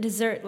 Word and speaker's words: Desert: They pronounce it dessert, like a piece of pie. And it's actually French, so it's supposed to be pronounced dessert.